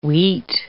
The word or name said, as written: weet